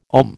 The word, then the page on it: ohm: American English